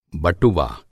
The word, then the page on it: बटुवा: Hindi